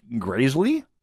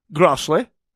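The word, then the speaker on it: Græsli: Okay, how do you pronounce that? Solveig Graisley? Yeah, you, you're you're close, and yet you're not close. It's Solveig Grossley. That's her name,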